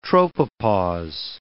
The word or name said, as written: tropopause